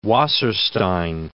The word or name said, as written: Wasserstein